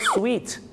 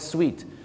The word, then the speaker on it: suite: ยครับ the suit suits you ก็แปลว่าสูตรตัวนี้เข้ากับคุณมากครับแต่ว่าพอคำว่าสูตร suit เพิ่มตัว e เข้าไปด้วยเนี่ยนะครับเหมือนกับอย่างเช่นที่ห้องโรงแรมที่เป็นห้องชุดมีทั้งห้องรับแขกด้วยเนี่ยชาวไทยไปบอกว่า I want the suit room I want the suit room มันไม่ใช่ห้องเก็บสูตรนะครับคุณผู้ชมห้องสูตรนะครับผมที่จริงแล้วต้องเรียกว่า suite suite ครับส่วนพี่น้องชาวไทยออกเสียงว่าห้องสูตรแต่ฝรั่งจะเรียกว่า sweet